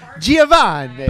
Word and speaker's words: Giovanni: I say it like an Italian. Giovanni.